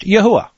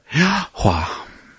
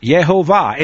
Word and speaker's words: Yahuwah: I believe, and you've heard me pronounce it, I believe that his name is pronounced Yahuwah or Yahuwah. Okay, it's probably not Yehovah. It's certainly not Jehovah, and it's for sure not capital L-O-R-D.